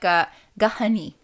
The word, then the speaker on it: gahani: It's like gahani. Gahani is how you say turkey.